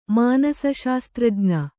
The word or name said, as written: ಮಾನಸಶಾಸ್ತ್ರಜ್ಞೆ